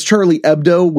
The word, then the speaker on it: Charlie Hebdo: Charlie Hebdo shooting. That's Charlie Hebdo with an H because it's French. This happened in France where the H is silent.